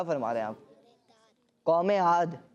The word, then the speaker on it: عاد: ابھی آپ کے پاس نہیں جانا میں نے۔ جی سورہ عاد،